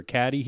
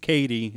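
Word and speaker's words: Cady: It also reminds me, too, of Mean Girls, where is there a Caddy here? And she goes, it's Katie, it's pronounced.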